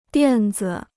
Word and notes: 垫子: diàn zi

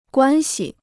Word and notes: 关系: guān xi

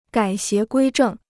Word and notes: 改邪归正: gǎi xié guī zhèng

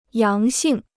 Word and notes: 阳性: yáng xìng

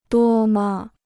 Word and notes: 多么: duō me

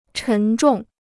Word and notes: 沉重: chén zhòng

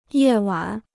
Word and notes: 夜晚: yè wǎn

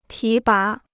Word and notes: 提拔: tí bá

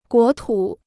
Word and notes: 国土: guó tǔ

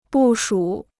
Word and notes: 部署: bù shǔ

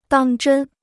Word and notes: 当真: dàng zhēn

